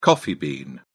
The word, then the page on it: coffee bean: British English